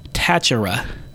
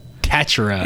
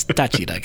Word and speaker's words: Táchira: But when Jason Ramirez signed, he pronounced his name as Jason Ramirez from Deportivo Táchira. – Táchira. – Yeah, and it hurt my ears. It hurt my ears. It's Táchira, guys.